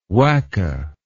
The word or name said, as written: wacke